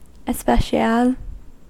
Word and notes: especial: American English

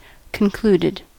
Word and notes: concluded: American English

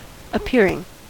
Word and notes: appearing: American English

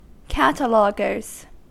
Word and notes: cataloguers: American English

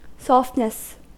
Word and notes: softness: American English

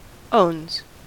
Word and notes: owns: American English

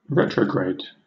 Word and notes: retrograde: British English

